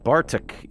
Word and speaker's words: BARTC: B-A-R-T-C. Pronounce it Bartok, even though it takes on the periodic elements for boron, argon, and technetium.